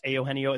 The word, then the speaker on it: Eugenio: Third baseman, and it should just be Eugenio or Eugenio Suarez, but it's Eugenio.